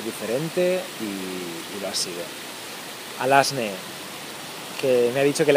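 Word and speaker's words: Alazne: No me acuerdo del apellido, pero se llama Alasne, me ha dicho que lo escriba con Z, pero se pronuncia con S.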